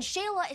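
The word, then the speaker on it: Xela: I signed us up for the Northside Kickball Classic this weekend, and Shayla is sponsoring our team!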